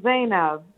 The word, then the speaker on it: Zainab: I hope I'm, am I pronouncing your name correctly? Let me get it straight. Zainab? – Hi, Mitch. Thank you so much. It's Zainab.